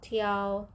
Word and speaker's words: 调: tiao, tiao, tiao.